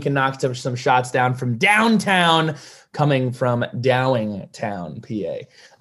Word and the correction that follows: Downingtown: It's Downingtown.